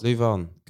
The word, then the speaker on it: Leeuwarden: Leeuwarden.